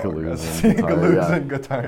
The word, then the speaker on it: Deleuze and Guattari: Deleuze and Guattari.